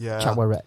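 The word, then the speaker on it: Chavoret: But before we do, how many executions did Shavaret? Am I pronouncing that right? Shavaret. – Chowaret.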